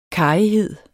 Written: karrighed

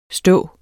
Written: stå

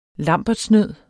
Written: lambertsnød

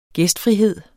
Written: gæstfrihed

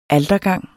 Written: altergang

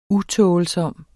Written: utålsom

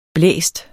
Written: blæst

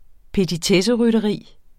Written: petitesserytteri

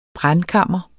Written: brændkammer

